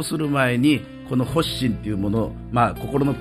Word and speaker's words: 発心: まず読み方なんですけど、それで、はい、初の心と書いてね、これね、発心と読むんですわ。 – 発心ですか、はい、発心、はい。